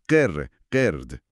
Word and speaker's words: قرد: و آخریش قرد، میمون با قاف. – بله، حرف قاف که باز از ته حلقه و قوی تر از قع تلفظ میشه. قر، قرد.